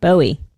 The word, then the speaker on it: Bowie: Bow? How do you say it? What? – Who Bowie, is it? Dude. Bowie.